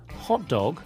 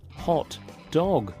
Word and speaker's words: hot dog: The stress is on the first word. So try saying hot dog with an equal stress on both words. Hot dog. This means a dog that is hot. Then say it again with the stress on the first word. Hot dog. That's a type of sausage snack.